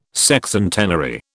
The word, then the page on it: Sexcentenary: British English